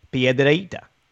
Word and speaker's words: Piedrahita: So it actually means little rock, Piedrahita. And the oh. H separates the A and I sound,